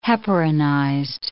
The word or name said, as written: heparinized